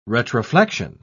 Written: retroflexion

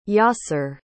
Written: Yasir

